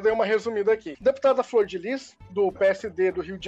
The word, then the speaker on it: Flordelis: E é impossível a gente não falar do caso da deputada Flor de Lis, que muita gente jurava que se pronunciava Flor Delis, inclusive eu.